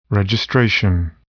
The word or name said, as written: registration